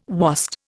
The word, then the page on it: wast: Indian